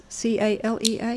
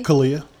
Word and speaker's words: CALEA: Chief, how do you say this? C A L E A? – CALEA.